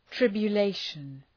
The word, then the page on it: tribulation: ,trıbjə’leıʃən